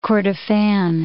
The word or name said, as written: Kordofan